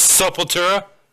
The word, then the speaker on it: Sepultura: I don't even know how to pronounce this one. Saltura, something like that. – Sepultura. – Yeah, Sepultura, that's it. Opeth, and then Lucana Coil. – Otep. Otep. O-T-E-P? – Yeah. Oh, no, O-P-E-T-H.